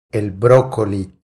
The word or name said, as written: El brocoli